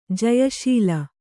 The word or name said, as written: ಜಯಶಾಲಿ